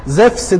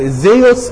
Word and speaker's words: زفس: زفس ده النطق اليوناني الحديث للاسم ان في عندهم اختلاف في النطق. هي بتكتب زيتا ابسلون ابسلون سيجما اللي هي يعني زي الزد زد اي يو اس تمام. فالمقطع الاي واليو دي في اللغة اليونانية الحديثة لما يجي بعديها حرف ساكن بتتنطق زف فيها بحرف الفه يعني.